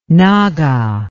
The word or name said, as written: naga